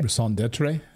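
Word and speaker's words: raison d'être: I'm reminding you that they're, how do you say this, Rasan Ditch? like your raison d'etre. – Yeah. Your reason for being.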